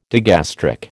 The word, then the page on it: digastric: American English